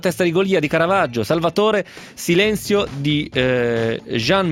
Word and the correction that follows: Joan: Miró. Joan, quindi la pronuncia è Joan.